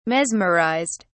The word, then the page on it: mesmerized: British English